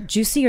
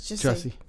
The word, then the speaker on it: Jussie: Juicy or Jussie? – It's Jussie.